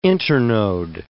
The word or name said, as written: internode